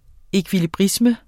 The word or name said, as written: ekvilibrisme